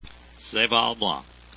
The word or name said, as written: seyval